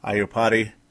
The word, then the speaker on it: Iupati: Vikings, of course, not able to get Iupati.